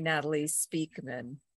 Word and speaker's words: Natalie Speakman: It's actually Natalie Speakman.